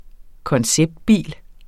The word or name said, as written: konceptbil